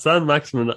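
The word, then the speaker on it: Saint-Maximin: Then, right on the cusp of halftime, I had a bet on San Mixaman. Why did I say his name like that? San Maximin and Patrick Bamford to score in the game.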